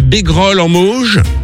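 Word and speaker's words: Bégrolles-en-Mauges: J'espère bien le prononcer, c'est dans le 49. Salut François. – Bonjour la suite. – Coucou, salut François. – Salut le François, c'est bien ça, c'est Bégrolles en Mauges.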